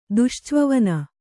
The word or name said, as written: ದುಶ್ಚ್ವವನ